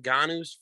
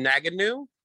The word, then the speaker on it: Ngannou: He calls him Naganu, Nunu, Namana, cannot pronounce his name at all. At the end, the announcer, Mr. Buffer, says Francis Ngannou, and you see Rogan look at him and go, shit.